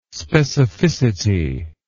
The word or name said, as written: specificity